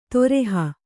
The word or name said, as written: ತೊರೆಹ